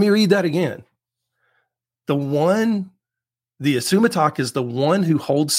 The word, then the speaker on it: Isumataq: Now, this word is from the native Inuit Alaskan language, and the word is ISUMATAQ. So you saw the title for today's episode. That word is pronounced ISUMATAQ.